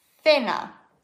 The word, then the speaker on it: cena: Da steht dann zum Beispiel, oder wenn du dich fragst, ich sehe jetzt ein Wort C-E-N-A. C-E-N-A, dann schaust du hier bei C, okay, C vor E, das wird gelispelt. Also musst du sagen, thinner, thinner. Das Abendessen heißt La Cena.